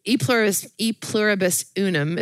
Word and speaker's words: E Pluribus Unum: But no, it's taking where the E Pluribus Unum, is that how you say it? – Yeah.